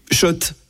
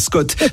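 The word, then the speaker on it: Schott: Oui, Shot et pas Scott. Ça fait des années qu'on prononce mal la marque.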